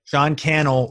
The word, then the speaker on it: Sean Cannell: Sean Cannell rhymes with YouTube channel.